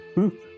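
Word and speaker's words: Hu: I'm going to be ready to cover the who? Is that how you pronounce it? The who? – Yes. H-U, yes.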